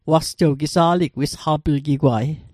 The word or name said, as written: wisqaplgigwa't